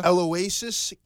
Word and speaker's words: Aloysius: Aloysius.